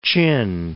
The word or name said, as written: Chin Hills